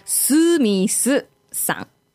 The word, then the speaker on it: スミス: So we don't have the any like a TH sound, so pronunciation. So スミスさん. – Sort of sounds like Smith. I can yeah. stretch it a bit.